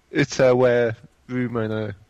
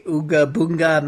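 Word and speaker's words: Utawarerumono: The Ooga Boonga Mask of Deception. – That's not even close. There's no G's in that.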